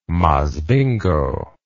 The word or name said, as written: Masvingo